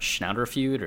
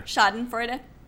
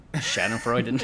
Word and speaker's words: Schadenfreude: And there's sort of that, how do you say that German word? Schnauderfeud or Schreudenfeud? Oh, yeah. Or Schadenfreude. – Schadenfreude. – There you go.